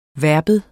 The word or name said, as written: verbum